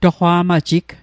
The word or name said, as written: toqwa'majig